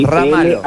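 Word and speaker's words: Ramalho: Serra Marro. – Ramalo, sí. – Ramalo. – L-H-O. L-H-O. – L-H-O, dame.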